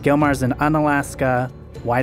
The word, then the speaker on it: Unalaska: Unalaska.